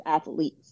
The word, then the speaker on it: Athleats: The best way is to go to my website, which the Athletes Nutrition is actually a play on the word athletes. So it's spelled ATHLEATS Nutrition. – Oh, nice. – So like, I like that. – Like eats. – Yeah. – And so some people get that confused, but it's athletes, like eats.